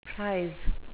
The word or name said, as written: prise